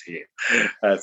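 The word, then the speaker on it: Eoin: It's spelled Owen, but pronounced Ian.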